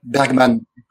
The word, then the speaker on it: Bergman: If that makes sense, which I think is quite different from Bergman, or Bergman, or the Swedish pronunciation police gets me. Is that close? – Yes, pretty much.